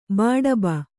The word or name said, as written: ಬಡಬ